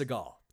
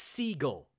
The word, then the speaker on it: Seagal: So first off, his name isn't even pronounced Seagal. It's Seagal. That's how it's pronounced. – As in the bird. – That's basically how it's pronounced. It's the Jewish pronunciation. His father was Jewish.